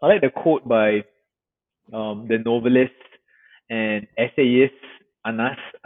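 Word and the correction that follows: Anaïs: Anais Nin.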